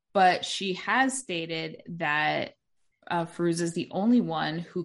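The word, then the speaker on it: Fairuza: Did I say it right? Fazuka? – No. Firuza. Yeah.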